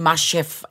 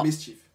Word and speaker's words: MSCHF: Det er måske mere sådan Masterchef, eller hvad fanden mere. Masterchef? Nå. Mischief. Mischief.